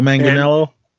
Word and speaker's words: Manganiello: And this time it's hosted by Joe Mangiella. How do you say his name? – That guy from True Blood? – Yeah. – Joe Manganiello? – Yeah.